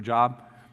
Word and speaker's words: Job: If you're looking for a job, there's a book in there. Well, it's actually Job. Yeah, okay. But it's spelled the same way.